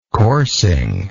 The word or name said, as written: coursing